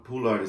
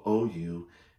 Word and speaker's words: Poullard: Poullard is spelled P as in Paul, Poullard. So a lot of people, you know, sometimes they get that Poullard right, sometimes they get it wrong.